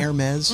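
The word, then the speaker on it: Hermès: So there was this really interesting profile on the news the other night about the artistic director of Hermes. Am I saying that right? – Yeah. – Hermes.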